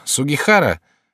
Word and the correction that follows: Суихара: Не Сугихара, а Суихара. Ты всегда так. Нельзя уродовать имена людей. Нужно быть внимательным. – Но пишется же Сугихара. – Пишется Сугихара, а произносится Суихара.